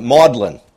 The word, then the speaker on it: Magdalene: In fact, even today, the term Magdalene, or as the Brits pronounce it, maudlin,